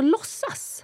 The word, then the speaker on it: låtsas: Ja, and you don't pronounce the T, so låtsas.